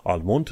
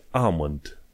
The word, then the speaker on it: almond: Almond nu se citește almond, se zice amond.